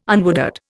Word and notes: unwooded: Indian

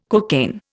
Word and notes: cocaine: Indian

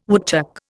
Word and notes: woodchuck: Indian

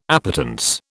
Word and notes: appetence: British English

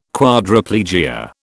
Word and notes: Quadriplegia: British English

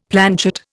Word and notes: planchet: Indian

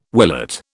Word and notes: willet: British English

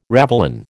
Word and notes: ravelin: American English

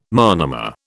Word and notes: monomer: British English

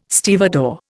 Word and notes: stevedore: Indian

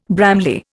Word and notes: Bramley: Indian

